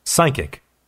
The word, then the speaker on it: psychic: psychic, psychic, 영혼의, 심령의. 여기도 마찬가지로 자음이 두 개 있을 때 피는 묵음입니다. 그래서 psychic이라고 읽으면 됩니다.